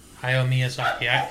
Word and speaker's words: Hayao Miyazaki: Say it right. – Hayao Miyazaki. – There you go.